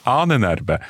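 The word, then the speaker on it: Ahnenerbe: Annenerbe, eller vad, hur uttalar man det? – Ja, du uttalar helt korrekt. – Annenerbe.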